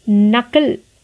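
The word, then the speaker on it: knuckle: नकल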